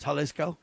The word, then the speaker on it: Jalisco: Jalisco, is that how you say it? Jalisco? – Right. Okay, is it with a J? – Yeah, it's Jalisco.